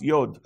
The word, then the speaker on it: yod: Okay, it's spelled J-O-D in your Bible, and right, it's pronounced yod.